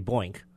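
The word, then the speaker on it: BOINC: It looks like Bo Inc., B-O-I-N-C. I don't want to say Boink, but...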